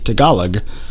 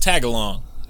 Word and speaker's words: Tagalog: I would like to have that relationship with her parents, but I think I need to learn to speak Tagalog, the Filipino language, before that's ever going to be a thing. So. – Oh, is that how that's pronounced? Because I always thought it was pronounced Tagalong.